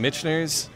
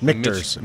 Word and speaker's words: Michter's: Michener's? I never say that right. – Michter's.